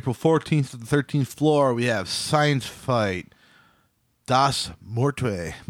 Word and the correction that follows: Muerte: Muerte. – I can't ever say it right. – Spanish. Muerte. – Muerte.